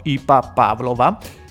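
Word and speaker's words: I. P. Pavlova: E il brano si intitola IP Pavlova. Che noi qui eh, a Praga pronunciamo Ipa Pavlova eh, ed è una fermata della metropolitana abbastanza importante, ci passo spesso perché io vivo a Praga.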